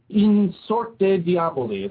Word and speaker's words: In Sorte Diaboli: In Sorte Diaboli. Is that pronounced right? – Yeah, sure, that's fine.